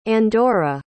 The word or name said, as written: andorra